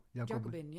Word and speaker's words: Jacobin: Over Kokanje of over Jacobin? Of zeg ik het goed zo? – Jacobin, ja. Dat is eh, correct.